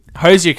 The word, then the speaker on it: Hoosier: Michael competed on the weekend at the Hoosier Cup. I think I'm pronouncing that right. Hoosier Cup. Okay, perfect.